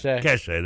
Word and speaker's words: cachet: You at least have one of the teams that has some cliche. Did I say that right? Cachet. There we go.